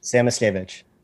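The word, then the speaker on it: Samoskevich: So is it Samoskovich? – Samoskovich. – Samoskovich, okay.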